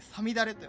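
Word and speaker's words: 五月雨: 5月雨と書いてさみだれと読むと、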